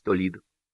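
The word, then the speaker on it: Toledo: Через час проехал Краков, Толидо — это тоже не Толидо, это Толедо, но по-английски читается Толидо.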